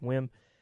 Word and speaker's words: WHIMM: After that, some post-punk stuff out of Toronto with WHIMM, I guess that's pronounced WIM, with a track called Second Sound.